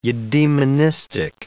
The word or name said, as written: eudaemonistic